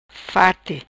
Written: farti

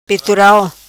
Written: Petoral

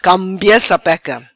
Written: Cambia Sapeca

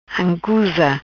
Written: Ancusa

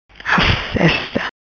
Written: Assesta

